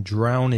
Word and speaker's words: drown: D-R-O-W-N, ow. The vowel sound in drown is ow, the same as now or cow. So drown.